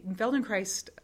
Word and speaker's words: Feldenkrais: And Feldenkrais, it rhymes with ice.